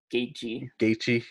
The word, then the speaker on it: Gaethje: Gaethje, even though it's spelled like Gaethje.